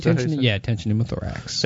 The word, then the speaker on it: tension pneumothorax: Tension pneumothorax? – Tension pneumothorax.